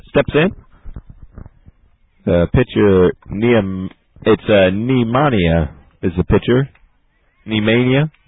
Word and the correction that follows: Niemania: we were pronouncing it wrong. It's Tori Niemania.